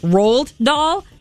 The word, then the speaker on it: Roald Dahl: But one of our coworkers, Alan, told me yesterday it's pronounced Roald Dahl, which he knows everything, so I'm just going to go with it that he's probably right. I always said Roald Dahl, but it's Roald Dahl.